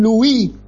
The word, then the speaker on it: Louis: con Ben Louis, que era el encargado del desarrollo del virus SARS-CoV-2, habiendo patentado otros, el 3, el 7 y otros, que desapareció, Ben Louis, se dice Lou, se escribe Louis.